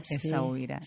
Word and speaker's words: Essaouira: Sí, Essaouira. – Essaouira, sí.